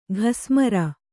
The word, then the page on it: ಘಸ್ಮರ: ghasmara